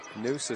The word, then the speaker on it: Njus: News for Busta for Turkey Valley. And it'll be Springville to inbound near sideline. – Noose is spelled N-J-U-S.